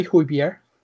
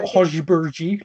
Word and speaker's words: Højbjerg: And to me, it's like, if it looks like you're going to build a team with him alongside Højbjerg, and yes, I can say Højbjerg. I learned how to say it. Not Højbjerg, like Gary Neville says.